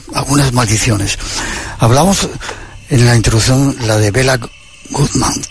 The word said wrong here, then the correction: Béla Guttmann: ¿Quién es? No sé si se dice así. ¿Quién era este personaje? – Pues Béla Guttmann fue un entrenador que nació en el año 1899 en Budapest.